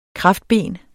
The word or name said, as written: kraftben